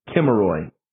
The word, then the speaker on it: Kimmeroi: That's how the Greeks pronounced it, the Kimmeroi. K-I-M-M-E-R-O-I.